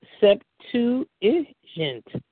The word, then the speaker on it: Septuagint: The oldest version of the Old Testament is a Greek version called the Septuagint, pronounced Septuagint, from the Latin word for 70.